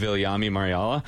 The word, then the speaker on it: Viljami Marjala: In the rookie game against Calgary last year, I wanted to interview Viljami Marjala. And the reason that I'm pronouncing that so right is because when he came out onto the into the tunnel, I called him Milhami Varhala, which is just horrific.